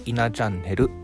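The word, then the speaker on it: 17チャンネル: 数字の 17 にカタカナでチャンネルと書いていただいて「いなチャンネル」と読みますが、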